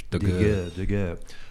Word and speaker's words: Daguerre: How do you pronounce his name in French? – Daguerre.